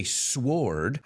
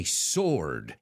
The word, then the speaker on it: sword: Y fijaos, la W no lo pronuncio. I don't say sword, I say sword.